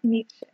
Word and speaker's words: Nietzsche: I'm going to say it wrong. – Friedrich Nietzsche. – Yeah. – That's how you say it.